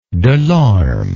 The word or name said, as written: delorme